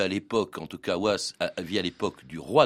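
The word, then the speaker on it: Wace: Alors les auteurs, les deux premiers en tout cas, Monmouth et, et, Was, c'est ça, c'est comme ça qu'on prononce son nom. – Was, oui, c'est un anglo-normand, donc faut prononcer à l'anglo-normand.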